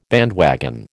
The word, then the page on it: bandwagon: American English